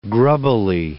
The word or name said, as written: grubbily